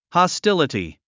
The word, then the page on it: hostility: hɑstíləti; ハスティリティ